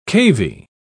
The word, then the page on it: cavy: British English